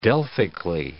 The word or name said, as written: delphically